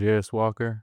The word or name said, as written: Jarace Walker